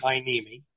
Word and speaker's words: Heiniemi: You wanted to be known by, as they pronounced it, Larry Hyneme.